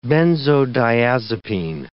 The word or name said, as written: benzodiazepine